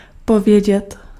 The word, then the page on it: dire: France (Paris); [diʁ]